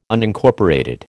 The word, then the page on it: Unincorporated: American English